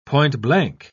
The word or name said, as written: point-blank